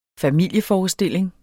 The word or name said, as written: familieforestilling